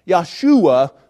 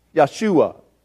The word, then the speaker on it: Yahshua: In Hebrew, they pronounced it Yahshua. Yahshua. It's spelled Joshua, but it's pronounced Yahshua.